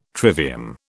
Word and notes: trivium: British English

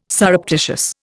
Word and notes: surreptitious: Indian

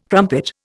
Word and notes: crumpet: Indian

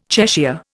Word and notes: Cheshire: Indian

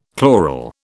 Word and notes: chloral: British English

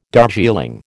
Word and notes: Darjeeling: American English

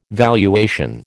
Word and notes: valuation: American English

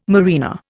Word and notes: marina: Indian

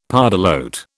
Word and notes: pardalote: British English

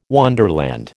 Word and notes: wonderland: American English

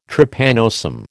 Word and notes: Trypanosome: American English

